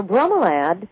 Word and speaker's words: bromeliad: Now, bromeliad is the way it should be pronounced.